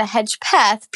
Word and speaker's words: Hedgepeth: It is Amanda Hedgepeth, P-E-T-H.